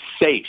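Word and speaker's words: seiche: Is it Seachy? Seach? How do you say this word again? What is this? – Seish.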